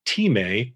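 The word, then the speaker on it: time (τιμή): There was a word time, T-I-M-E, with a long E, time,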